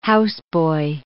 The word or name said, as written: houseboy